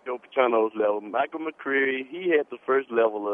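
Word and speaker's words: McQueary: But you did pronounce it right, McCreary, which is exactly what he is, not queer as in homosexual, a queer bird.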